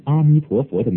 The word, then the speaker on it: 阿弥陀佛: 行者要念南无阿弥陀佛，阿弥陀佛的阿最好别念成哦音，阿是开口音，和哦的发音差别很大。念时阿弥陀佛，各字音节若稍微拉长一点更佳。这一个要点我曾经再三讲过，希望大家不要忽略。我们所念阿弥陀佛